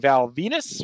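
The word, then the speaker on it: Val Venis: Raven defeating Val Venus, rhymes with. I'm supposed to say penis.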